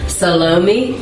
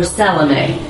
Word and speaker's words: Salome: And her name was, you can pronounce it one of two ways, Salome or Salome.